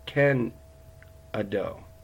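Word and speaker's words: kenegdo: But when he talks about her being a helper, the Hebrew word is Ezer Ken Ado. And I'm probably butchering that last part, but it's K-E-N-E-G-D-O, Ezer Ken Ado.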